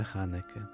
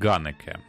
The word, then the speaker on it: Ханеке: Ну, деякі перекладають як Ганеке. Ну, це вже питання до філологів, тому що H читається як... – По-німецьки було би Ханеке. – Да. Ну, Ханеке, Ганеке. Якщо Ганеке побачите, то це те ж саме, що Ханеке, да.